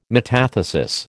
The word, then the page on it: metathesis: American English